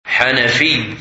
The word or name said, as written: Hanafi